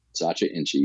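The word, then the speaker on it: Sacha Inchi: I've learned so much about this, even just learning about, I can never pronounce it, Satcha Inchi? – Satcha Inchi, you got it.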